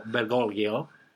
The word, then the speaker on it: Bergoglio: and it emerged that Argentinian Cardinal Jorge Mario Bergoglio, is that how you say it? – Very nice. Well done.